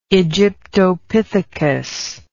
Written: aegyptopithecus

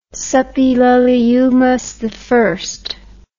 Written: suppiluliumas i

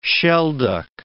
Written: shelduck

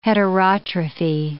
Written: heterotrophy

